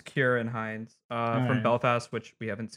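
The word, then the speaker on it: Ciarán Hinds: Oh, Kieran Hines.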